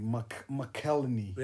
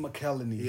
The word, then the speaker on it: McElhenney: Hey, McElhenney. – Yeah, Rob McElhenney. – McElhenney.